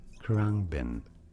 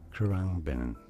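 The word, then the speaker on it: Khruangbin: How do you say it? Corungbin. Corungbin. Pretty straightforward once you know. Corungbin.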